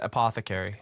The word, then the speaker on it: apothecary: Is it apothecary or is it apothecary? – I think you said it properly. However you said it, you said it right.